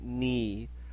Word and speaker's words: knee: Y knee no se pronuncia la K. So it's only knee.